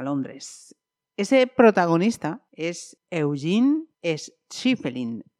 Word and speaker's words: Schieffelin: Le voy a preguntar a esa autora si lo primero lo acabo de pronunciar bien. Karen Santos, bienvenida y gracias por este tiempo, antes que nada. – Muchas gracias y gracias a ti. Sí, lo has pronunciado bien, aunque yo creo que, lo has hecho un poco difícil. Schiffelin creo que sería más fácil.